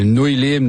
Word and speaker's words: Nouille Libre: Ouais, Nouille Libre. Nouiller Libré, c'est comme tu l'entends.